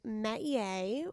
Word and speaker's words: métier: It is métier, which means a vocation, trade, an area of activity in which one excels, a forte.